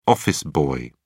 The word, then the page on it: office boy: British English